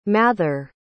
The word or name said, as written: mather